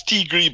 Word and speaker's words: Tiger: It's Tigre, but it's spelled Tiger.